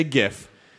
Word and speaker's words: GIF: And they say, but the guy who created the graphical interchange format says GIF. And I go, yes, Will Hite was a programmer. He was not a linguist. Hard G before the I.